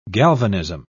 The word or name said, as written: galvanism